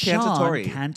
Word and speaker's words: Cantatori: I feel like I say it wrong. – I think you did say it wrong at one of the shows. But I think it's Cantatori. – I think you're right.